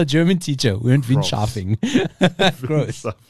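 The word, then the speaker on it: Groß: Gross. Gross.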